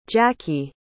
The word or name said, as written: jackee